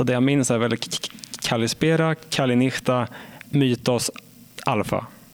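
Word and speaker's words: Kallispera (Καλησπέρα): Kallispera? – Kallispera, ja. Det var ett bra uttal faktiskt.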